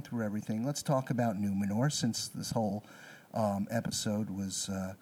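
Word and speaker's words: Númenor: And now know that it's pronounced Numenor. – Numenor.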